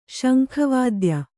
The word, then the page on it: ಶಂಖವಾದ್ಯ: kannada